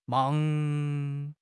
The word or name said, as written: mang2